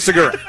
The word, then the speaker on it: Segura: Is that how you pronounce his name, Segura? – Yeah, Gene Segura. – Gene Segura.